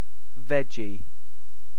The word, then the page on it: veggie: British English